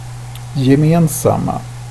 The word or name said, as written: gemensamma